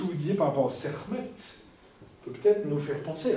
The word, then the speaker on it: Sekhmet: Alors, vous avez fait le rapprochement avec Sekhmet ou Sekhmet, puisque les cas sont prononcés plutôt.